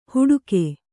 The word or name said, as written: ಹುಡುಕೆ